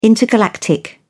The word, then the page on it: intergalactic: British English